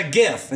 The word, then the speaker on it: GIF: Well, for those of you who call it a GIF, it's a GIF. It's a hard G. Graphics. – Yep. – It's not giraffics.